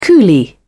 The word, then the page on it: coolie: British English